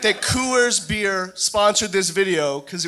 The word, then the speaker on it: Coors: And the word Coors has one syllable. Coors. – Coors. – K-O-O-Y-E-R-S.